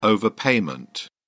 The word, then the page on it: overpayment: British English